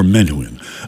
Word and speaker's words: Menuhin: His name is Yehudi Menuhin, M-E-N, that's M-E-N-U-H-I-N, okay, Menuhin, or Menuhin. Menuhin is probably the correct pronunciation.